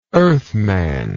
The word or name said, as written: earthman